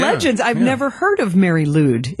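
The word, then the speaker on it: Mari Lwyd: I would call it the Mary Lude rap battle.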